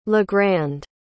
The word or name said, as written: legrand